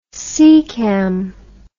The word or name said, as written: secam